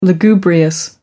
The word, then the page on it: lugubrious: American English